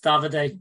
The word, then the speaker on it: Davide: Yeah, I think we're a little bit away from the likes of, is it Davide Ancelotti? Davide? Like, David, how do you say his name? David? Okay, sorry, I'm being honest. – He's getting called David. – Yeah, it's Davide.